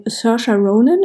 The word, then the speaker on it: Saoirse Ronan: Es geht mehr oder weniger um die 13-jährige Bryony Tellis, die gespielt wird von Saoirse Ronan. Ich hoffe, ich habe es richtig ausgesprochen. – Ich glaube, ja, so spricht sie zumindest selber ihren Namen aus.